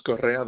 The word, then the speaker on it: Correa: Last name's Correa. That's spelled C-O-R-R-E-A.